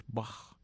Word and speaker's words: Bach: El caso es que la CH en alemán suena como J. Y se escribe Bach, pero es Bach.